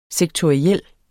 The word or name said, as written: sektoriel